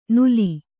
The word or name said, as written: ನುಲಿ